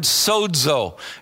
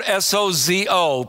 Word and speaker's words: sozo: It's the Greek word sozo. It's spelled S O Z O, pronounced sozo.